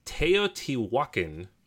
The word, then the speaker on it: Teotihuacan: It's Teotihuacan, City of Gods.